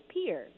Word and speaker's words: Pierre: So it's spelled Pierre, and Chris Lecoq, our producer, who is indeed French, says only the Yankees would say things weird like that. But it's Pierre. That's how they say it here.